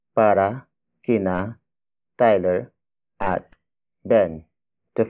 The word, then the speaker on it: para: Up next, we have para. Para. Para. Para is spelled as P-A-R-A.